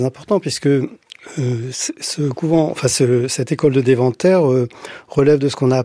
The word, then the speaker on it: Deventer: Et notamment, en Hollande, il y a une célèbre école des frères de la vie commune à Deventer, c'est comme ça qu'on prononce.